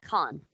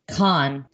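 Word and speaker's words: Cannes: Is it Cane Music Fest? I mean, Movie Festival? Cane's? – Con. – Con. Thank you.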